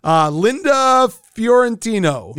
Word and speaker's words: Linda Fiorentino: Linda Fiorentino.